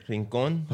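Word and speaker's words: Rincón: Rincon.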